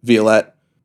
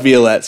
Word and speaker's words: Violette: And then Chelsea and I, Violette. It's pronounced Violette, so we can just continue like that.